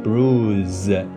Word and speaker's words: bruise: 另外一个呢，布鲁斯，嗯，就是 B R U C E，B R U C E，两个呢书写上面区别非常大，但是发音的时候呢，一个读成 bruise，一个是 bruce。那因为这一个小的事故呢，this little accident，我又反思了一下，那英语中确实有很多类似的例子。就是呢，两个词读音基本上是一样的，区别就在于 s 和 z。